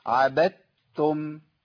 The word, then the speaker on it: عبدتم: طب أنطق الدال؟ لا ما تنطقش، حضرتك ما تنطقش الدال، ولا حضرتك تنطق الدال، الدال دي كأنها مش موجودة خالص. أمال أعمل إيه؟ أنتقل من الباء للتاء على طول المشددة، أقول إيه؟ عبدتم.